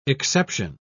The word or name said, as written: exception